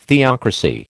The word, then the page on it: theocracy: American English